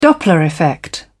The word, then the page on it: doppler effect: British English